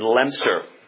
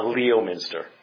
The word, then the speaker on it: Leominster: Notice that's what I'm told is the pronunciation if you're there. It's not Leominster. One of the glories of the English language is that words aren't pronounced the way they look.